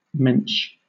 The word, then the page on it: mensch: British English